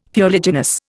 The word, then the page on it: fuliginous: Indian